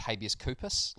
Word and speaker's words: Habeas Corpus: That username will be pronounced Habeas Corpus, like Habeas Corpus, which is a...